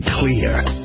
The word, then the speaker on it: Xlear: Introducing Clear. It's pronounced clear, but it's spelled X-L-E-A-R.